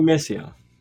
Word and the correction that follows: Misra: Misra? I can't get her name right. It's a TD security strategist, Pira Misra. The strong data will force the central bank to remain aggressive and pull economic activity lower.